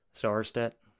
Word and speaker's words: Sarstedt: Sarstedt.